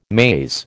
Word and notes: maze: American English

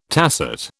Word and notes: tacet: British English

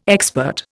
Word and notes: expert: Indian

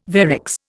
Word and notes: varix: Indian

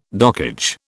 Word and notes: dockage: British English

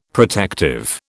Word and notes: protective: British English

